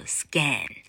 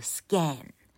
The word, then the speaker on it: scan: 啊，你现在看到 n n e r，你把子 i n g 拿掉，也就是你把 n e r 拿掉，它的原型就暴露出来的，它念作 scan，因为现在 a 在两个子音中间，叫做单母音。落到老客发音的第一条秘籍，好，单音节以及重音节要念 a，所以它一定念着 scan，好，加字尾以后念着 scanner，scanner，OK，好，那么浏览的 browse，ow 是 KK 音标系统认可的第十六号单位，哈，ow 是一个双母音。